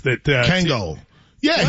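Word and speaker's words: Kangol: Kangor hats. – Kango. Yeah, what? He's in. – Did I say it wrong? – Yeah, Kango hats. You said Kangor.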